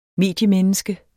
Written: mediemenneske

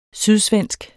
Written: sydsvensk

